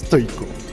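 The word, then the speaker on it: toicho: Et effectivement, au mot croisé ou au Scrabble, c'est un sacré mot puisqu'il y a T-O-I-C-H-O, hein, sans tréma sur le I. J'y tiens, c'est du grec, donc on prononce toiko.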